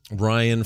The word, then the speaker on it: Ryan: Ryan from Michigan wrote in. Rhymes with Brian, mm-hmm.